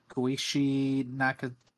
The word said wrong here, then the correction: Koichi Nakanishi: Alex, how do I pronounce this? – Koichi Nakashish, that's a hard name to pronounce. – Koichi Nakanishi.